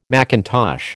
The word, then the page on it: mackintosh: American English